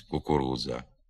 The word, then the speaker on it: кукурудза: На місці букв «дж» та «дз» вимовляється не один звук, а два. Наприклад, «джерело» замість «джерело», «кукурудза» замість «кукурудза».